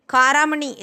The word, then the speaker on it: காராமணி: Karamani is spelt as ka ra ni karamani.